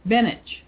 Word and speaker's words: Beneš: It's the duty of You know that Beneš is actually pronounced in Czech, Beneš. – Really? – Yeah, that's the same way as some of those other names. I don't know why that is, but that's the way they pronounce it. But then most people, you know, who aren't thinking about that and still you call it Beneš.